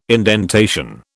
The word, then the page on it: indentation: British English